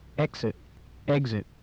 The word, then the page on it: exit: English